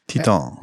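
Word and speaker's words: Titane: I think you would say Titan. – It's French. The movie is French, so that's how you would pronounce it. – Titan.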